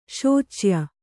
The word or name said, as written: ಶೋಚ್ಯ